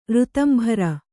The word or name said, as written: ಋತಂಭರೆ